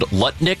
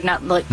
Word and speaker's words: Lutnick: Howard Lutnick. – Howard Nutlick. – No. Howard Lutnick. – Howard Nutlick. – Stop it. Howard Lutnick.